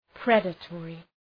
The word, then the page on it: predatory: ‘predə,tɔ:rı